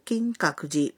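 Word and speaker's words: কিং কাকুজি (金閣寺): এবার অন্য আর কি কি কাজ করতে চান তা বলা অনুশীলন করা যাক। ধরুন আপনি কিয়োতো নগরীতে অবস্থিত বিখ্যাত কিং কাকুজি মন্দির দেখতে যেতে চান। মন্দিরের নামের জাপানি উচ্চারণটি লক্ষ্য করুন, কিং কাকুজি।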